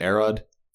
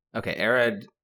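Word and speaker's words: Arod: I missed that, but I always pronounce it A-Rod because if I say A-Rod, I think of Alex Rodriguez, the baseball player. – Okay. A-Rod.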